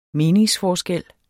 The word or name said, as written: meningsforskel